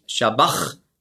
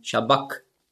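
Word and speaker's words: sabach: Und das CH im Griechischen, das ist ja ein Buchstabe, das Chi, das hat man ausgesprochen so wie ein C hinten im Gaumen. K. Also nicht Shabach, sondern Shabak.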